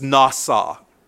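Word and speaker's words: nasa (נָשָׂא): First of all, the Hebrew word for forgiveness is nasa. Nasa, N-A-S-A, N-A-S-A, pronounced nasa, N-A-S-A.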